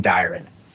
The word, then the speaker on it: Dyron: And first off, let me say, I'm just impressed that you pronounced it correctly the first time. Thank you for not butchering the name Dyron.